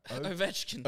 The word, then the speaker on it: Ovechkin: Ovechkin. – Ovechkin.